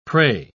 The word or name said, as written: prey